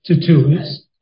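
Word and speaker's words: Tertullus: Tertullius. – Yeah, you said it right.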